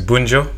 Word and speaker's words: Bunjo: Uh, my name is Bunjo.